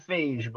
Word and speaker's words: Feige: It's spelled phage, but whatever. It's spelled like beige would be spelled, so therefore it's pronounced phage.